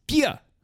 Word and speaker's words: Bier: Bier! Sorry, I mispronounced it. Bier!